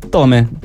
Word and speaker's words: Tomae: Perché si scrive Tomae, sì, però effettivamente Tome.